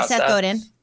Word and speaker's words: Seth Godin: Yeah, Seth Godin.